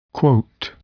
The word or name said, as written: quote